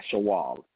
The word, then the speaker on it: Shawwal: But then, as you mentioned, the Shawwal, did I pronounce that right? – Yeah, Shawwal.